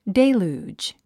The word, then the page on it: deluge: dél j uːdʒ; デェルージ